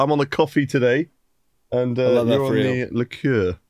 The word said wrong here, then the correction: LaCroix: Yeah, it's actually pronounced LaCroix, and I think it's originally from the Midwest, but it looks like this is made in California now.